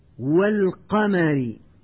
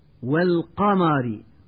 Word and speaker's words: والقمر: والآن مع الدرس الحادي والعشرين ومع القراءة الصوتية الثانية والتي سأحاول من خلالها تنبيه المتعلم إلى الأخطاء النطقية الشائعة في الكلمات التالية: والقمر، ويقول من يخطئ والقمر بتفخيم حرف الواو وحرف الميم وحرف الراء،